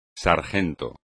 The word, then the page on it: sargento: espagnol